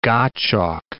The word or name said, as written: Gottschalk